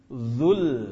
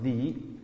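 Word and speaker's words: dzu (ذو): Dzul jalali wal ikram. Dzu dibaca dzu, bukan dza atau dzi, karena dia mengikuti kepada wajhu, bukan mengikuti kepada Rabbi.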